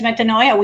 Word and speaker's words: metanoia: metanoia.